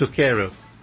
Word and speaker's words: zucchero: It's spelled Z-U-C-C-H-E-R-O. – Mm-hmm. – And it's Sukero, apparently. – Mm. – But I've got a CH in there, so I call it Zucchero.